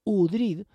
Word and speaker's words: Oudrid: Bellísima fantasía y, desde luego, excitante El sitio de Zaragoza, de Cristóbal Oudrid, o Udrid, si se pronuncia a la francesa, como supuestamente era su padre, que era de origen franco-flamenco.